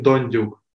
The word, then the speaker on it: Dondyuk: Maxim Dondyuk.